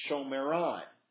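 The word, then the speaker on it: Shomron: But Samaria, if you were literally were going to pronounce it, it's Shomeron.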